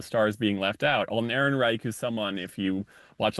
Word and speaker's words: Ehrenreich: Ehrenreich, is that how you say his name? – Ehrenreich. – Ehrenreich, thank you.